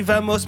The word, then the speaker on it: Vamos: Oui, ça s'écrit Vamos, mais ça se lit Vamos. En Espagne le V se dit B, mais ça c'est limite.